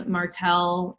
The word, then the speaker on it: Martel: My my Instagram name is just my whole name, Martel, and that's spelled with one L, um, Catalano.